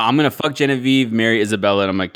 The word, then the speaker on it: Genevieve: Genevieve. – Yeah. – But you got to say it more French. – Yeah. Genevieve.